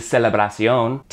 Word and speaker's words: celebración: Celebration in Spanish is celebración, T-I-O-N to C-I-O-N with an accent on the O, but other than that, those are the same words.